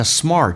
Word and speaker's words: smart: 首先我们看一下 smart 这个单词，基本上很少人发对，就是因为嘴巴张得太大了。其实这个发音近似于发澳大利亚的 O，然后重度卷舌 smart，因为你无法张到最大来进行卷舌。 smart 的 t 不发音，气流要阻塞，因为 t 前面是一个元音，叫 smart， smartphones，